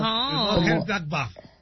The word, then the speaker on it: Mönchengladbach: Mönchengladbach.